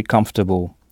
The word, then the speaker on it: comfortable: Mais attention, « comfortable » s'écrit avec un « m » et non un « n », c-o-m. Comfortable, C-O-M. Vous voyez que c'est pratiquement le même mot qu'en français, mais la prononciation est très différente. Comfortable. Pas de son on, puisque ce son n'existe pas en anglais. Et ici, l'ensemble C-O-M se prononce come. Et notez que O-R est à peine audible, comfortable, et que c'est la première syllabe qu'il faut frapper.